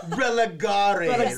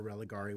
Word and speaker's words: religare: Religare. – Say it with confidence, religare. And no one will question you. – It's pronounced religare, not religare.